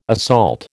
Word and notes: assault: American English

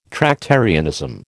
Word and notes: Tractarianism: American English

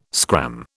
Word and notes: scram: British English

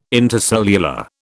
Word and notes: intercellular: British English

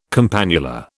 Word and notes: campanula: British English

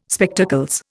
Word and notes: Spectacles: Indian

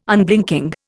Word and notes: Unblinking: Indian

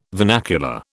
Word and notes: vernacular: British English

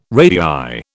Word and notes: radii: British English

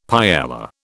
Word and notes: paella: British English

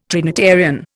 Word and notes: Trinitarian: Indian

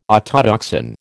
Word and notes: autotoxin: American English

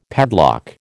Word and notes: padlock: American English

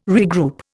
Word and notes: regroup: Indian

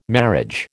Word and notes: marriage: American English

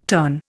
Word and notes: terne: Indian

